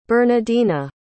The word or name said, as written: bernadina